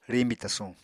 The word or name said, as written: riñbi tasuñ